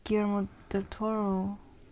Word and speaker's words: Guillermo del Toro: But then the Guillermo del Toro, am I saying that right? – Yeah.